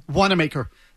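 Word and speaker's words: Wanamaker: And again, how do you pronounce the name? – Wandemaker.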